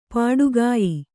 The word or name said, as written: ಪೊಟ್ಟುಗೆಯ್